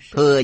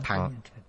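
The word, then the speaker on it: thặng: Thừa giả, âm cổ là thẳng. Này chúng ta thường đọc là thừa. Âm cổ đọc là thẳng, có nghĩa là xe.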